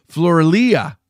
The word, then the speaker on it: Floralia: How do you say that? Floramo? Tommy Floramos. No, Floralia, the festival of Flora, the Roman goddess of Flora, held on April 27th during the Roman Republic area.